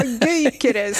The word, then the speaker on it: Gyökeres: Ja, Gyökeres säger man.